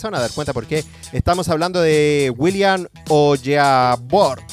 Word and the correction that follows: Onyeabor: A ver, no, ¿cómo se pronuncia bien? Onyabor, eso.